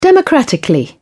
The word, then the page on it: democratically: British English